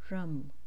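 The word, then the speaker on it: Ram: Manipur's beach mantra or seed sound vibration is Rum, phonetically spelled as R-U-M.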